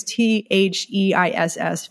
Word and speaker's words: Theiss: Tyss, I think that's how you say his name. It's Theiss, famous Star Trek costume designer. We've talked about before, but I always pronounce his name wrong.